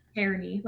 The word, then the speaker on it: Cerny: I feel like Noah Cerny, is that how you say it? – Charity, like Charity.